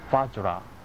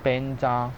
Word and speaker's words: Vajra: Om Ahom Vajra Guru Padma Siddhi Hom。以上念诵的咒是莲师的根本咒，是梵语的发音，而西藏文念诵是 Om Ahom Benja Guru Bema Siddhi Hom。其中不同的就是 Vajra 和 Benja，或者他们西藏文会念诵 Besa，其实都是金刚的意思，但是梵语的念法就是 Vajra，西藏就是 Benja 或者 Besa。